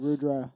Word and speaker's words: Rudra: It's like rolling your R's. If you grew up doing it, you can. If you can't, you can't. So I have a lot of people that are like, oh, I want to say your name properly. I'm like, you can't. Just say it the way I do. It's Rudra. Just run with it.